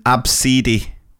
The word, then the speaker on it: Abcde: I've also taught an Absidi. Absidi. Spell A-B-S-I. No, no, it's not spelled that. That's how you pronounce it. Absidi. Spell A-B-C-D-E.